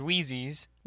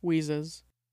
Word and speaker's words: Wheezes: Weasley's Wizard Wheezy's. – Wheezes.